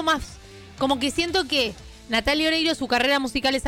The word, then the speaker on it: Natalia: Las rusas que escuchan a Natalia Oreiro deben ser copadas, porque se pronuncia Natalia.